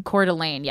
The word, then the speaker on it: Coeur d'Alene: How did we say this? Do we remember? – I think Shane said Coeur d'Alene. – Coeur d'Alene, yes.